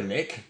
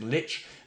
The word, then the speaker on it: Lich: We harmonise on Lich, many prefer Lick, but we've established that's incorrect, Lich, and Tiamat is Tiamat.